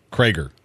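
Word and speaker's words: Creager: So what was your pronunciation? – Krager. – Krager. Okay, we're going to go with Krager. – And this is him pronouncing his name. – Hey, y'all, I'm Roger Krager, and you're tuned in to Krager.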